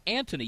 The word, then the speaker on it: Anthony: And if you go to Europe, his name is pronounced Anthony. You don't say the H.